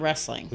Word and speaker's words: rasslin: Well, it's not wrestling, it's wrestling. – Wrestling. – R-A-S-S-L-I-N.